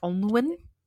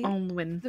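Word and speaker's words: Onllwyn: Oh, I can't pronounce it. It's near Port Talbot in Wales. It's like Onwen. – I think it's pretty Onwen. – The way they pronounced it in the film, it was pretty straightforward. What is it? How do you spell it? – O-N-L-L Win.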